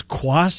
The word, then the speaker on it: Cuas: I think his name is Quas, C-U-A-S. Is that how you say it? – I believe so, too, yeah.